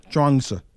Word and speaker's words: Chuang Tzu: Am I saying that right? I know. Yeah, Chuang Tzu.